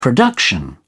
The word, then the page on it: production: British English